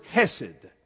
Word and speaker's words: hesed: The word is hesed. Now, we transliterate it C-H-S-E-D. We pronounce it without the C sound, hesed, H-E-S-E-D.